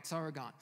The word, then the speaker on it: Sauron: Am I saying that right? Any nerds want to? Yes, all right, Sauron.